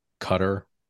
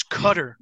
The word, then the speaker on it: Qatar: Oh, and by the way, you guys are mispronouncing the name of our country wrong. It's Qatar, not Qatar. And Adams handled it greatly, but my whole thing was like, well, I'm speaking English, so in English it's Qatar. If I'm speaking in Arabic, it would be Qatar.